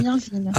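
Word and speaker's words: Myrddin: ça s'écrit Myrdine, mais on voilà. dit Mirzine.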